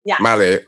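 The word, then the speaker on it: Malé: How much, apart from, I think you pronounce it Male. – Male, okay, which is the capital city.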